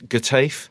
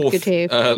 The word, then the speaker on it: Getafe: I believe it's Getafe. – It is indeed Getafe.